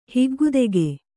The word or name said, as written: ಹಿಗ್ಗುದೆಗೆ